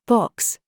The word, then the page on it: BOB: British English